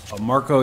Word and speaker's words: Marko: Marko Jokanovic, M-A-R-K-O, last name J-O-K-A-N-O-V-I-C. – Could you spell that J-O-K? – A-N-O-V-I-C.